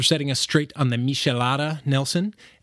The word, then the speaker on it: Michelada: The correct pronunciation for the drink is Michelada.